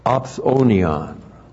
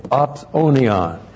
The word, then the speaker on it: opsonion: And then we have a very unique and rare Greek word, opsonion. O-P-S, long O, N-I-O-N, opsonion.